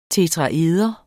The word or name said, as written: tetraeder